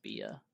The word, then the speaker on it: Bia: I don't even know when it came out, but it's Bia, or I think it's Bia. That's how you say her name.